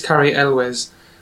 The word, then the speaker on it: Elwes: I'm never sure how to say his name. It's Cary Elwes. Cary Elwes. And he was Eloise? – In... Eloise? I don't know. No, I think it's Cary Elwes.